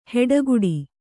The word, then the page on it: ಹೆಡಗುಡಿ: heḍaguḍi